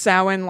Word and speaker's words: Samhain: I only found out that it's pronounced Samhain like, I don't know, a year ago.